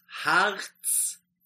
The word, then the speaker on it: Hartz: That's Hartz 4.